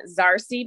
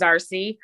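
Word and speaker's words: Zarse: I have an Amazon page at Jane Zarcy. My first name is Jane, J-A-N-E. My last name is spelled Z, the last letter A-R-S-E. It looks like Zars, but it's pronounced Zarcy. And my first book, like I said, is Love and Compassion is My Religion.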